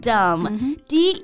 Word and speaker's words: dumb: 那最后一个表达教给大家，日常当中啊用的比较多的，也很可爱，dumb dumb，d u m b，但是大家注意最后一个字母 b 是不发音的，所以呢也可以直接写成 d u m。